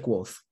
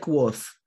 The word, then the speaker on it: Kvothe: Se pronuncia Quoth. Quoth. Se pronuncia Quoth, pero se escribe K-V-O-T-H-E.